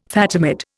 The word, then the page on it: Fatimid: Indian